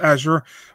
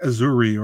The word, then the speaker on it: Azure: Weirdest pronunciation ever of a fighter's name, Azure. If nobody told me it was Azure, I was going like Azuri or something.